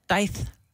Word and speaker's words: Daith: D-A-I-T-H. Daith.